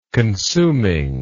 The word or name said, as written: consumingness